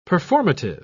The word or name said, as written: performative